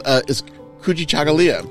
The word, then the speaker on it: Kujichagulia: On day two, and I'm always working on pronouncing this, is Kujichagulia.